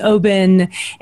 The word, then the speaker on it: Oban: So when I met him, he was all about the Oban and the Lagavulins. – Good pronunciation, because a lot of people will pronounce that Oban, but you hear it perfectly there.